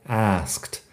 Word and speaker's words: asked: Es un verbo regular, o sea que la forma en pasado asked es con SK y el sonido de T, asked. Técnicamente es así, asked, pero en realidad se acorta en la conversación.